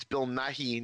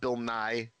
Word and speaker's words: Nighy: No, but, and since I've confused it before, it's Bill Nighy, not Bill Nye.